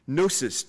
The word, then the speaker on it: gnosis: Gnosis, G-N-O-S-I-S, this Greek word, gnosis, knowledge, the G is silent.